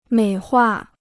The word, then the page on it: 美化: měi huà